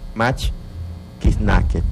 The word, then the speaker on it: mach kiznaket: Que en lengua yocotán se pronuncia mach kiznaket.